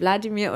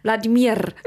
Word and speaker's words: Wladimir: Und dann halt die ganze Zeit nicht so, wie Deutsche sagen würde, so Wladimir oder so, sondern nein, Wladimir.